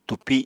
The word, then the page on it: túpi: Apatani